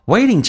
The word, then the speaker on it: waiting: Stop waiting， waiting 这个单词美式发音 T 要被浊化，两个元音中间所加的辅音都要被浊化，所以 T 发成 D，而且 ING 发成 IN，后鼻音发成前鼻音的形式，叫 waiting。